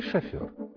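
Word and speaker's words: шофёр: Запомните, пожалуйста, правильно говорить «шофер» с ударением на последнем слоге, так как это слово пришло к нам из французского языка. – Слово «шофер» – буква «ё», поэтому и ударение в этом слове на последний слог.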